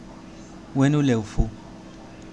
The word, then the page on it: Huenuleufu: Mapuche